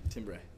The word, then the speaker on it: timbre: Versus going because you're hitting the different strings, you're getting a different timbre, if you will. – Yes. – Timbre. – Timbre. Is that timbre? – It's timbre. – It's pronounced timbre, everybody. – T-I-M-B-R-E.